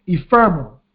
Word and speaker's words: ephemeral: Or that word we used last week, they are ephemeral. Ephemeral. E-P-H-E-M-E-R-A-L. Ephemeral. That's how you pronounce that.